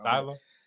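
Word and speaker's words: Tyla: Tyler.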